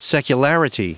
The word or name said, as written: secularity